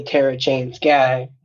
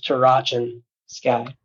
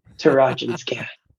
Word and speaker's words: Tarachan Sky: But we didn't want to say Terra Chain Sky. Like, we wanted to be unique, so we decided to pronounce it as Tarachan Sky.